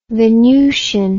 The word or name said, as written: venutian